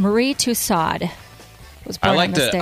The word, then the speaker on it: Tussaud: Madame Tussaud. – I knew that was a setup. – The wax museum. I don't speak French. – The wax. No, and you shouldn't. We should pronounce it like Americans.